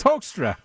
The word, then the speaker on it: Hoekstra: It's Hoekstra.